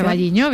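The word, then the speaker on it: O Carballiño: Ocarvalliño, no lo sé pronunciar. – Ocarvalliño, bien, Ocarvalliño.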